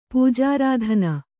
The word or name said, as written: ಪೂಜಾರಾಧನ